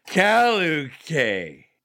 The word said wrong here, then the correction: Caliche: Caliche. – Well, I mean, how do you say it? Caliche.